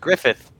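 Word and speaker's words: Gruffudd: Ian Griffith. It's pronounced Griffith.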